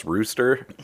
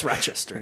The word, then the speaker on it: Rochester: You sure it's not pronounced Rooster? – Is it? Is that Rooster? – No, it's Rochester.